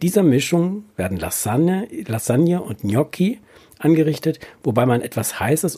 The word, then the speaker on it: Gnocchi: Du hast es auch gut ausgesprochen. Gnocchi.